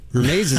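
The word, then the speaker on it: Hermès: It's got a silent first letter. Hermes is spelled with an H, and Pfizer is spelled with a P.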